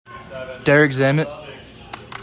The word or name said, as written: DEREK ZAMMIT